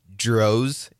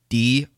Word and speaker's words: Drozd: Last name is Drozd. It's spelled Drozd.